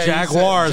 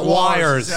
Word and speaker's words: Jaguars: Jaguars, not wires.